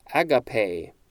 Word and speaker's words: agape: Agape. That is how this one is pronounced, A-G-A-P-E. The pronunciation guide is telling me this is agape.